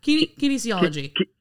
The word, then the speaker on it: kinesiology: Kinesiology.